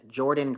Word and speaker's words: Jordynne: You guys can find me on all social media platforms under the name Jordan Grace. I know I didn't put it here, which was dumb, but it's spelled J-O-R-D-Y-N-N-E.